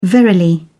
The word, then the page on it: verily: British English